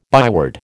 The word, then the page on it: byword: American English